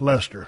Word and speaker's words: Leicester: Oh, pronounced Lester. Of course, he had to put that after he made me pronounce it wrong.